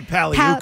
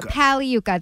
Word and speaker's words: Pagliuca: I say Pagliuca. – Pagliuca, that's what it is. – Oh, I said it right.